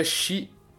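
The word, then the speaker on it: chi (四): O número 4, ele, você não soma, ele é como se você tivesse tirado zero, porque o número 4 se pronuncia chi Na, na, minha pronúncia lendo japonês, tá, gente? Considerem aí, dá um, dá, uhum. dá um boi aí pra mim. É, mas se pronuncia chi, que é a mesma pronúncia da palavra morte.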